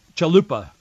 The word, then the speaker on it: Chalupa: He worked uh, with the DNC operative, Alexandra uh, Chupala. They, this morning, a little while ago, about an hour ago, they moved that Alexandra uh, Chalupa, that's the way you pronounce it, Chalupa, uh, that her name be removed, redacted, because they didn't want anybody to know who she was.